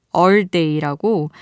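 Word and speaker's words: All Day: All Day라고 표기를 ERRR day라고 해서 어떻게 읽어야 하나 했는데 all day라고 읽는다고 합니다.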